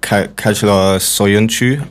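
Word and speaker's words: Çağlar Söyüncü: I think this Turkish centre-back, Kajlar Soyuncu, I believe you pronounce it, they signed him last year.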